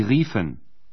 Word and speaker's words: riefen: O U é substituído por duas vogais, I, E. A pronúncia é I prolongado. – Rufen. Sie riefen.